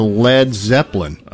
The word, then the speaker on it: Led Zeppelin: Drummer Keith Moon, when invited to play for a new band, thought the idea would go over like a Led Zeppelin. And they changed the spelling because they didn't want people to use the wrong pronunciation of lead.